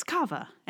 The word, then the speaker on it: kava: How do we say this word? Kava? Kava? Kava. It's kava.